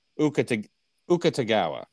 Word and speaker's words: Akutagawa: I actually looked up, I had to look up the phonetic pronunciation. I looked it up. So it looks like it's Ryunao Suke is the first name, and the last name would be Akutagawa.